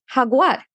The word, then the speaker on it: jaguar: In Spanish, the letter is Jota and makes the H sound. So the word jaguar is actually a perfect cognate. It's actually spelled exactly the same in Spanish, but it's pronounced jaguar.